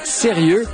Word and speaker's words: SRX: Ça s'appelle Sérieux. Ça s'écrit SRX, hein, les jeunes auront compris.